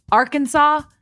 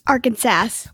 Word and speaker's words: Arkansas: Per state code, the only acceptable pronunciation is in 3 syllables, with the final S being silent, the A in each syllable with the Italian sound, and the accent on the first and last syllables. I'm not exactly sure what that means, but I'm pretty sure you pronounce it Arkansas, and you could get fined if you do it wrong.